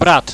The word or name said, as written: brat